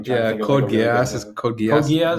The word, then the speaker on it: Geass: Geass.